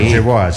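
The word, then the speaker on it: Yeboah: John Jeboar. ¿Lo dije bien o lo dije mal? – John Jeboar, sí.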